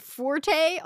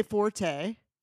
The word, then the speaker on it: forte: I would say forte. I would say this is your forte. – That's what I would say. – They say that as well. – E is silent, that it's fort.